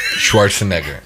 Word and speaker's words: Schwarzenegger: Schwarzenegger.